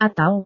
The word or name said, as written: atau